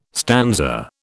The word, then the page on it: stanza: British English